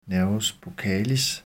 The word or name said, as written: n. buccalis